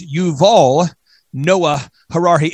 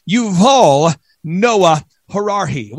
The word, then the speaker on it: Yuval Noah Harari: How do you say his name? It's Yuval Noah Harari. InfoWars Nation, say it with me now. Yuval Noah Harari.